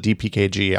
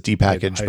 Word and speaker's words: dpkg: He calls it dpkg. I call it, I've always pronounced it as dpackage, but whatever.